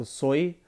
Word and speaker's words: soy: So soy, like soy sauce. So we have soy sauce, but soy in Spanish means I am.